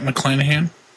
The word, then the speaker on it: McClanahan: Yep, McClanahan.